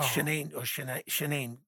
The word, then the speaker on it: Shanae: I think you actually pronounced her name correctly, because it was like Shanae.